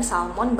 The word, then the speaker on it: salmon: Soalnya kayak salmon gitu kan salmon bacanya salmon ya terus apalagi ya yang LS sound yang kayak silent pronunciation itu